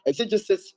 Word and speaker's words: exegesis: Okay, exegesis, let me spell it for you. E-X-E-G-E-S-I-S, exegesis. Then the second word I said is eisegesis or eisegesis. E-I-S-E-G-E-S-I-S, exegesis and eisegesis.